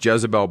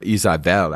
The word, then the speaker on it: Jezebel: Actually, her name is not Jezebel. It's pronounced Jezebel by English speakers of the Bible, but it's actually Isabel, actually, because there's no dagesh in the bait. So it's Isabel.